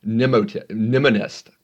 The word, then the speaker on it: mnemonist: And with the case of S, Luria's paper was called The Mind of a Mnemonist. It's really hard to say, mnemonist, the mind of a mnemonist. With that MN, that weird funky MN, silent M beginning.